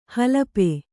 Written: ಹಲಪಿ

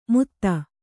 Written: ಮೂಧ

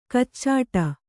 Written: ಕಚ್ಚಡ

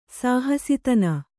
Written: ಸಹಸಿತನ

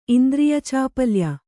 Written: ಇಂದ್ರಿಯಚಾಪಲ್ಯ

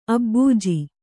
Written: ಅಭಿಜೆ

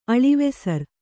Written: ಅಳಿವೆಸರ್

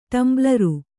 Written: ಟಂಬ್ಲರು

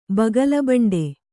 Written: ಬಗಲುಬಂಡಿ

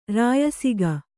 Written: ರಾಯಸಿಗ

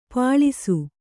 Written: ಪಳಿಸು

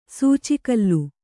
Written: ಸೂಚಿಕಲ್ಲು

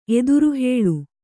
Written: ಎದುರುಹೇಳು

